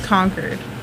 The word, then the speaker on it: Concord: And drive to Concord. – Yeah. Concord. – Concord. Concord. – Yeah. Concord. – Mm-hmm. Like, Concord. Like, it's been conquered.